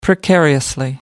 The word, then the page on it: precariously: American English